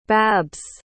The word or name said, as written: babs